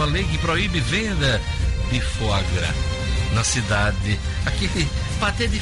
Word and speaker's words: foie gras: Que a pronúncia certa é foie gras.